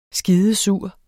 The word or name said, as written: skidesur